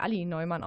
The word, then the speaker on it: Alli Neumann: ähm, das ist Ali, übrigens wird die Ali Neumann ausgesprochen.